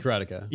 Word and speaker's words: Karateka: He remade, is it Karateka? Is that how you pronounce it? – Remade Karateka.